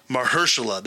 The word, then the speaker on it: Mahershala: Mahershala. That's how you say it. Okay, I was right.